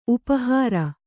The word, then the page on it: ಉಪಹಾರ: upa hāra